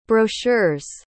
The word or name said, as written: brochure's